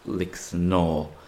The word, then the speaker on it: Lixnaw: In English it's spelt Lixnaw, but locals pronounce it Lixnaw, which is much closer to its Irish name of Lechnava.